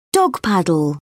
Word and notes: dog paddle: British English

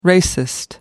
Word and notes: racist: American English

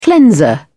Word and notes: cleanser: British English

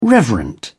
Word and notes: reverent: British English

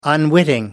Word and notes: unwitting: American English

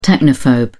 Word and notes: technophobe: British English

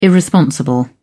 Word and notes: irresponsible: British English

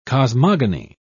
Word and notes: cosmogeny: British English

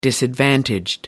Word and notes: disadvantaged: American English

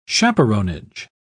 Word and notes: chaperonage: British English

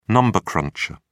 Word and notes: number cruncher: British English

